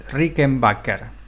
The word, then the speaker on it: Rickenbacker: Eh, y mira, y lo vengo pronunciando mal, recién caigo, es Rickenbacker. Eh, toda la vida la publiqué Rickenbacker, la, la, la, publiqué, la, la nombré Rickenbacker y me cuesta sacármelo, pero bien pronunciado es Rickenbacker.